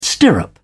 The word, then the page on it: stirrup: British English